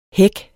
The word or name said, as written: hæk